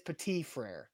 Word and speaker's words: Petit-Frere: I think the last T is silent. I think it's Petit-Frere. – Okay. Well. – Yeah, no hard T in the end.